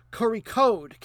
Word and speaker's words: Kozhikode: They're from specifically a town, and I've got to get this pronunciation right because there's no R in the name, Kozhikode, K-O-Z-H-I-K-O-D-E, at least Latinized, Kozhikode.